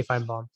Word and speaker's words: Feinbaum: Like you have to vocalize it. How do you say Feinbaum? – Feinbaum.